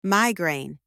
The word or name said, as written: migraine